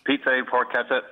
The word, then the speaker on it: Pizza e Porchetta: I hope I'm pronouncing that correctly. At any rate, it's in Dublin's Grand Canal Dock. Hello. How are you today? – Hey. – The name of your restaurant, will you pronounce it for us, please? – Pizza e Porchetta. You were dead right.